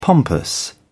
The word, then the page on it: pompous: British English